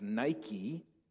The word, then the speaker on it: Nike: The Greek word for overcome is Nike. You'll recognize it with a little different pronunciation as Nike, means to overcome.